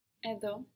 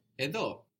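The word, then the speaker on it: Edo (ἐδώ): Edo. – Edo. Where is the accent on these words? Where is the stress on these words? Ego, Edo. – At the end. – At the end, good.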